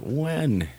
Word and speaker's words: Nguyen: It's spelled N-G-U-Y-E-N. Nguyen is probably how you'd want to say it, but it's pronounced Wen, and he is an illustrator.